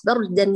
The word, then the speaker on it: بردا: الكلمة بردا يا غاليتي بكل بساطة إذا شعرت في كلمة أنها ثقيلة عليك في النطق حلليها. با بر دا بر دا بردا بردا. – أيوة بردا. لا تعملي سكتة بين الراء والدال، لا وصليها.